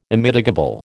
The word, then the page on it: Immitigable: American English